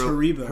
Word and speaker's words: Haribo: Did you say Hasbro? – How do you say it? – I think Hasbro. – It's Haribo. – Haribo. That's right. Hasbro's a toy.